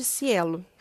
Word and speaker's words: SciELO: Tem a plataforma Sucupira também e o site Cielo, que se escreve assim, S-C-I-E-L-O.